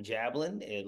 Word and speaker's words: Jablin: My name is Adam Jablin. Last name is spelled J-A-B-L-I-N.